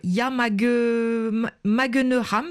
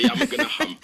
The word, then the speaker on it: Yamagenaham: Et votre ONG a lancé pour les fêtes un jeu de société qui s'appelle en Wolof Yamagenoram, c'est bien ça? Yamagenoram? Est-ce que je le prononce bien déjà? – Oui, tu prononces bien, c'est Yamagenaham.